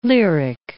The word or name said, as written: lyric